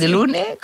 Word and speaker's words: De Lune: Louise de Lune? O sí. De Lune? Com? – De Lune. – De Lune. És clar, és un nom que es pronuncia en francès, oi? – Sí.